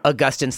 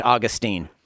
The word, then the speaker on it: Augustine: We talked about everyone from Polycarp to Augustine / Augustine. – Yeah. – And we dealt with the great controversy of how to pronounce the name. – Yeah, depending on which side of the Atlantic you're on.